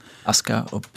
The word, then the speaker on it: Asuka: Asuka, volgens Japanse uitspraak. – Asuka? – Asuka, ja. – Oh ja. Oh, maar daar staat wel een oet tussen. – Ja, die valt weg.